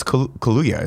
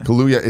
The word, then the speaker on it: Kaluuya: Oh, it's Kaluuya, isn't it? – Kaluuya. It's not. It is. Oh, yeah, it is. – Kaluuya.